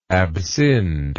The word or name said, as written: abscind